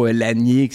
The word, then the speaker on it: Lasnier: Les prénoms de famille, mettons, Lanier, qui s'écrit L-A-S-N-I-E-R, on le prononce pas.